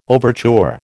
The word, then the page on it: overture: American English